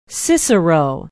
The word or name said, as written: cicero